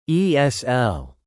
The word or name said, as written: ESL